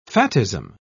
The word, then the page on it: fattism: British English